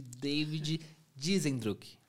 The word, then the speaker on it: David Diesendruck: Hoje está aqui comigo ele, o Victor Alexandre, que vocês já conhecem, mas temos aqui a presença ilustre de David Dizendruck. Falei certo, né? certo.